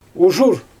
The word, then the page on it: uʒur: Xyzyl